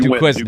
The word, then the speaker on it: Duquesne: Duquesne.